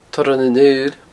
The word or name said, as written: torran an aoil